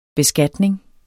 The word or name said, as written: beskatning